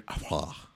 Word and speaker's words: Affleck: And I realize we've been saying Ben Affleck when I think it's pronounced Ben Affleck. – Ben Affleck. – I say Affleck. – Anyway. – Silent K.